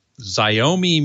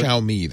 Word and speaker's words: Xiaomi: Midnight Rider had asked me, have I heard of a short throw laser projector called the Xiaomi Mi? I have heard of it. I've never seen it or tried it. But it is one of a... – Xiaomi, that's pronounced.